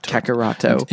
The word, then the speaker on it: Kakarot: Kakaroto. – Until you tell me to stop. – That's correct. Oh, that is the correct way to say it.